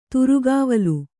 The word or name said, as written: ತುರುಗಾವಲು